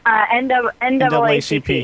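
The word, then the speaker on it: NAACP: And what is, you won a double A-N-C-P. Did I say that right? Double A-N-C-P? – N-double A-C-P. – Is that what I said during the break? Did I say double A-C-P or something? – You said something that was wrong.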